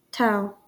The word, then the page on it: tau: ˈtaʊ; ˈtɔː; ˈtɒ